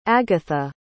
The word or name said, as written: agatha